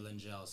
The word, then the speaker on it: Lengyel: It's Hungarian, and a lot of it's pronounced Lengyel. So even don't even pronounce it the right way. – Okay. – But I grew up pronouncing it Lengyel.